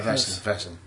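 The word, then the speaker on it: Wesen: because all German pronunciations, it's all German pronunciations. I think they pronounce Wessen. – Yeah, Wessen. – Wessen, yeah.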